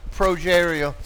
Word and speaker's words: Progeria: Progeria.